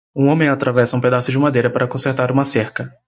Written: consertar

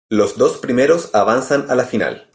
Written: avanzan

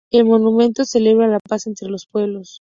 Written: monumento

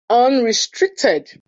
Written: unrestricted

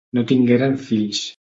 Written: tingueren

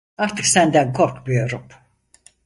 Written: senden

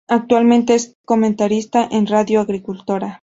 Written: comentarista

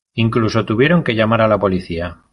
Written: llamar

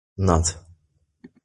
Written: Not

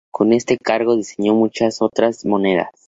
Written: monedas

